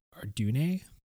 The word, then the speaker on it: Ardunae: So that's spelled Ardunae. I just say Ardune.